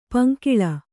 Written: ಪಂಗೋಳಿ

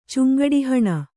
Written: ಚುಂಗಡಿಹಣ

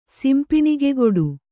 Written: ಸಿಂಪಿಣಿಗೆಗೊಡು

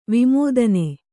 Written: ವಿಮೋದನೆ

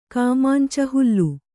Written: ಕಾಮಾಂಚ ಹುಲ್ಲು

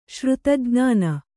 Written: ಶ್ರುತಜ್ಞಾನ

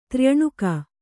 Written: ತ್ರ್ಯಣುಕ

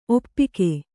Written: ಒಪ್ಪುಗೆ

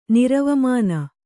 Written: ನಿರವಮಾನ

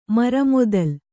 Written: ಮರಮೊದಲ್

